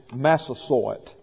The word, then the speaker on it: Massasoit: And I'll spell that, and I've looked it up in Webster's, and the pronunciation is Massasoit.